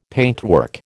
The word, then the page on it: paintwork: American English